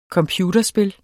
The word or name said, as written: computerspil